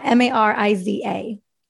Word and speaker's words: Mariza: Mariza.